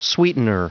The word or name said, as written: sweetener